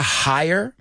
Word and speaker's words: higher: Cuidado con esta, porque la G de higher no se pronuncia, right? La palabra higher, más alto, H-I-G-H-E-R, se pronuncia igual que contratar, H-I-R-E, right?